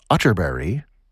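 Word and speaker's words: Atterberg: This is from the Swedish composer Kurt Utterberry. It's spelled Utterberg, but pronounced Utterberry, just in case you were Googling.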